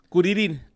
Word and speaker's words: Kuririn: Kuririn, así es como se escucharía la pronunciación de en japonés.